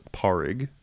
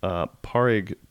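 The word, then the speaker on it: Pádraic: I believe it's pronounced Porrig, P-A-D-R-A-I-C. – Okay. – Porrig Pierce Paddy McGinnis.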